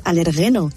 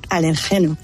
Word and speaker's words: alérgeno: En quiero decirle a Carlos que no se dice alergeno, se dice alergeno.